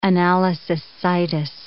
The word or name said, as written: analysis situs